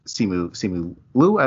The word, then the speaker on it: Simu Liu: between Tony Leung and Simu Liu.